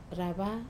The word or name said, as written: rava